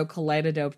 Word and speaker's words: Kaleidodope: She's on Instagram at both Kaleidodope and also Kaleidodope Tarot. Kaleidodope is spelled like kaleidoscope, but with a D instead of an SC.